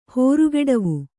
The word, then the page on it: ಹೋರುಗೆಡವು: hōrugeḍavu